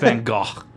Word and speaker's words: Van Gogh: it's pronounced Van Gogh.